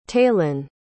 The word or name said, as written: taelyn